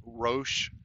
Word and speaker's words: Roche: I did read something today that they're actually lifting the bureaucracy, and the FDA has approved this, what they call, I guess it's pronounced Roche, R-O-C-H-E, test that is going to fast track the testing and get results back much quicker than the other test.